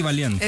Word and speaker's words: Valiant: Eddie Valiant. – Es que estaba pensando como un valiente, pero lo dije mal. Valiant, tener que actuar con estos personajes que realmente no estaban ahí.